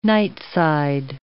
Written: nightside

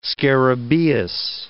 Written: scarabaeus

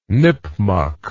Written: nipmuck

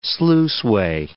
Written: sluiceway

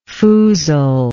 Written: foozler

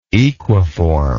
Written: equiform

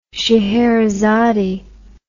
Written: sheherazade

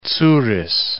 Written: tsouris

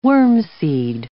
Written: wormseed

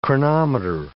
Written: chronometer

